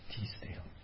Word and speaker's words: Teasdale: Teas, T-E-A. – T-E-A-S. – Yes, that's the name of the person. Teasdale.